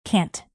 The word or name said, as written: cant